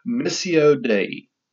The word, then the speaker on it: Missio Dei: It's called, all right, now y'all know, I'm going to pronounce Latin with a Southern accent. I probably don't pronounce Latin properly, but it's Missio Dei. That is spelled M-I-S-S-I-O-D-E-I. Missio Dei.